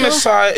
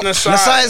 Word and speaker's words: Nasir: Yes, King Nassai is an N-A-S. – Like Nas. – N-A-S-I-R.